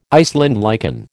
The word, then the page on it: Iceland lichen: American English